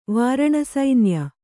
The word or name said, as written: ವಾರಣಸೈನ್ಯ